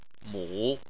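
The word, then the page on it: mu: tone 3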